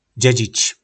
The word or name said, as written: Dzedzic